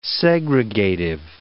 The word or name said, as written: segregative